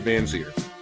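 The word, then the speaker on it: Vanzeir: How do you say his name? – Dante Van Zier.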